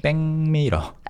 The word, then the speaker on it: 백미러: 거울인데 한국어로는 back in English, back mirror. – 네. – 그래서 발음이 재밌죠. – 네. – 백미러. – 아, 백미러. – Yeah. People rarely say back mirror. They say 백미러.